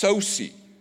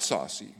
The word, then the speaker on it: Sosi: I know it looks like saucy, it smells like saucy, probably. It's pronounced Sosi, not saucy. Mark Sosi is his name.